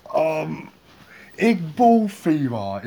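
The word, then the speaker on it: Iqbal Theba: Igbal Fima, is that how you say his name? That's how it looks anyway. – Yes.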